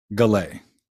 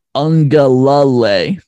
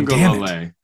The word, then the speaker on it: Uiagalelei: Galay. – Galay. – No. Ungalalay. – Oh, Ungalalay. Uwe Ungalalay. – There's another la in there. – Uwe Ungalalay.